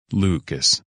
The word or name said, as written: Lucas